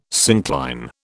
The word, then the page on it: syncline: British English